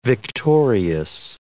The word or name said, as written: victorious